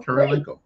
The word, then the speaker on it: Kurylenko: Kurylenko. And you said it right. Olga Kurylenko. Yep.